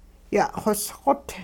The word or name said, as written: ya'xosqot